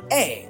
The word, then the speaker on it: heir: Now, the word an heir, you see, when somebody is an heir or heir, be an heir, but an heir, because the H is silent, an heir to the throne.